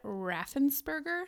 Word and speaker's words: Raffensperger: Okay, but shout out to the Republican Secretary of State of Georgia, Brad Raffensperger.